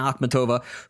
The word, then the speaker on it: Akhmatova: Akhmatova? Akhmatova? Okay, Anna Akhmatova, who was excommunicated by the Soviet regime in 1946.